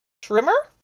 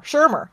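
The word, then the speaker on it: Schirmer: One of the earliest cases being that of Herbert Trimmer? Shermer.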